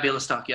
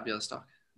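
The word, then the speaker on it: Białystok: but in what year was Chris's club Bialystok? Is that how you say the second part of it? – Yeah, Bialystok. Yeah, Bialystok.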